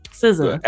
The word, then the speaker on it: SZA: And quickly, I was able to get that information, and I probably pronounced the name wrong in error, but you know, and I apologize for that, but SZA. – Yeah, there you go.